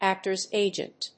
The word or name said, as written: actor's agent